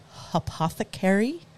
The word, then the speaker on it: Hopothecary: Okay, and they're called Hopothecary. Did I say it right? – You did. Perfect.